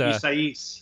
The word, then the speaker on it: Isaias: And of course, he just had Hurricane, I always have trouble pronouncing it, Isaiah, I think is how it's pronounced. – Isaias. – Even though it looks like Isaiah. Oh, Isaias.